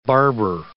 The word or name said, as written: barber